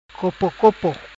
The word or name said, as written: Copocopo